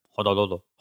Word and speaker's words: Honolulu: No, that's how they say it. – Honolulu.